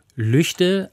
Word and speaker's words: Lügde: Denn viele Menschen, die nicht im Nordrhein-Westfälischen oder im Westfälischen oder eigentlich müsste man sagen im Lippischen leben, denen wird die Aussprache Lüchde angesichts der Schreibweise erstmal eigentümlich vorkommen und halten das vielleicht für falsch.